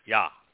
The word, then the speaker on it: Yah: So at the beginning, in the midst of this widespread pollution and profanity, Yahweh, YHWH, first letter is easy to pronounce. It's pronounced like the consonant vowel in English, the yes, for example. Yah. The ah sound is from the hey. The hey is a vowel. It is almost always pronounced ah. So it is yah.